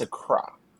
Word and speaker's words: Accra: Accra is the capital of which African country? And yes, that is how you say it. It's Accra.